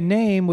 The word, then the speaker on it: Naim: But anyway, Name, which is spelled wrong, N-A-M-E, but they spell it N-A-I-M. It's the brand.